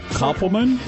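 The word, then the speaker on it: Kopelman: Starting with the first big D-I-V-O-R-C-E. – Yeah. – Drew Barrymore. – Third time. – And her husband, Will Koppelman.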